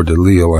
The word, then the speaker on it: de Lisle: And a man named Jean-Claude Rouget de Lisle, or de Lille, I suppose it would be pronounced in French, saw these men marching under his window with their muskets and pitchforks and whatever they had.